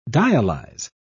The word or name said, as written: dialyze